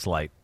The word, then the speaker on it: Licht: It's spelled Licht, but it's Light.